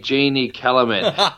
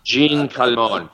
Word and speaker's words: Jeanne Calment: I've always pronounced it Jeannie Kellerman. That's the way, that's my Aussie pronunciation. Jeanne Calment.